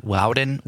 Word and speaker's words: Loudon: I don't know how you say L-O-U-D-O-N. Loudon.